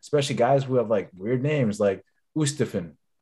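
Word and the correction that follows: Oosterhuizen: that dude. – Oosterhazen. – There we go.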